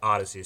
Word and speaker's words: Audacy: A-U-D-A-C-Y, pronounced just like Odyssey.